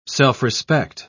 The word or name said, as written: self-respect